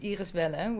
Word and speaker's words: Iris Welle: In Dutch, my full name is pronounced Iris Welle, which is quite hard in most languages, so I just call myself Iris on the internet.